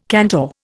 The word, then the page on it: cantor: Indian